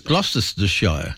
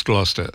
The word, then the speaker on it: Gloucestershire: How do you is it Gloucestershire or Worcestershire? Gloucestershire. – It's Gloucestershire. I'm probably saying it wrong. It's spelt this way. – Yeah, it's pronounced Gloucester. – Gloucester. It's got a Estershire. – Gloucester, so you can say Gloucestershire.